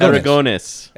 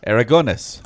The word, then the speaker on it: Aragonés: Sergio Aragonés. Is that correct? – Aragonés. – Aragonés. – Aragonés. Okay. – I like his... – Sorry, Aragonés.